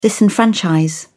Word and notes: disenfranchise: British English

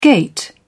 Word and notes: gait: British English

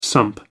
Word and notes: sump: American English

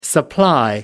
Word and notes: supply: American English

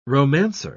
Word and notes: romancer: British English

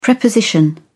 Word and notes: preposition: British English

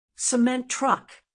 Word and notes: cement truck: American English

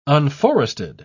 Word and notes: unforested: British English

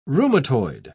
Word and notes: rheumatoid: British English

